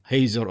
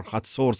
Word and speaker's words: Hazor: Hazor or Hatzor, some people pronounce it.